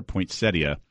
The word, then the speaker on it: Poinsettia: And we actually called a year ago to ask him if it was Poinsettia or Poinsettia. And it's Poinsettia.